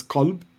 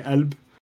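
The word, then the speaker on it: Qalb (قلب): The correct classical Arabic translation is Qalb with a hard K. In the Levant, where I'm from, it's a glottal stop, so we'd say Elb. Golb is how you'd say it in the Gulf, so that's also a valid a pronunciation.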